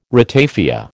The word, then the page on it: ratafia: American English